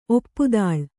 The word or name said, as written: ಒಪ್ಪುದಾಳ್